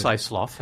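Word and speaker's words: Siceloff: And John Sisaloff, I'm sorry, I mispronounced your name, didn't it? Is Sisaloff? – It's Sisaloff, that's right.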